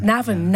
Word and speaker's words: Navan: Navan, yeah. – Navan, yeah. Okay, that's the Irish pronunciation, the right pronunciation.